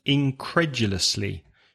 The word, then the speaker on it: incredulously: Okay, nearby, and incredulously, stress the second syllable. Now, that's a long word, five syllables, incredulously, incredulously, stress the second, incredulously.